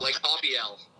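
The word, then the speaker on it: Haubeil: It's that your kicker, your starting kicker, Blake, I want to say his name, Blake Haubiel? – Blake Haubiel. – Haubiel.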